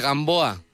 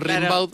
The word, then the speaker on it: Rimbaud: Gimbaud. – Ahí va. – Como se pronuncia Rimbaud, pero es Rimbaud.